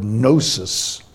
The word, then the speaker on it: gnosis: it's the silent G. The Greek word gnosis means knowledge.